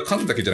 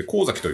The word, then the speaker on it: 神崎: ちなみにこれは神崎じゃなくて、神崎と読みます。